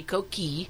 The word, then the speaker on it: coquille: Properly in French, it should be coquille, but a lot of people don't know that two L's and an E aren't heard in French.